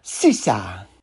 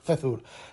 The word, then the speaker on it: Czur: una C, una Z, una U y una R, que por los vídeos que he visto por ahí se pronuncia Sisa. Pero vamos, nosotros somos apañoles y decimos Cezur.